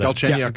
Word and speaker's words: Galchenyuk: Galchenyuk.